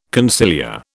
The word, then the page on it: conciliar: British English